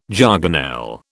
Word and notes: jargonelle: British English

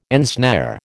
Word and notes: ensnare: American English

